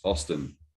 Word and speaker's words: Austen: My name is Craig, your host, and today we are joined by Ross Austen.